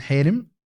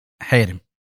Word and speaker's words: حارم: فهي عموما حارم هذه قلعة مركزية ودائما دائما محل ها اي تمام، يعني تنطق الآن حارم هذا ايش اللهجه الحلبيه ولا عالي ادلب حيرم.